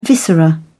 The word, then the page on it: viscera: British English